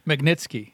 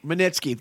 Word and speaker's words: Magnitsky: So yeah, Magnitsky. – Magnitsky.